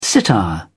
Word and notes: sitar: British English

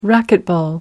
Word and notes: racquetball: British English

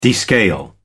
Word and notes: descale: American English

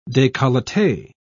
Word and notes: decollete: British English

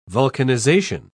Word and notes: vulcanization: British English

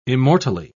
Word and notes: immortally: British English

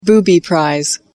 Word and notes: booby prize: American English

